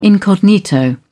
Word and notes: incognito: British English

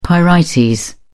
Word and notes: pyrite: British English

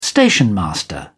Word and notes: stationmaster: British English

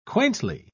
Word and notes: quaintly: British English

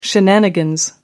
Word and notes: shenanigans: American English

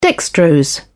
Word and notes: dextrose: British English

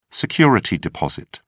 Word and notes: security deposit: British English

